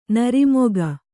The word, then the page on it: ನಿರ್ಮೇಘ: nirmēgha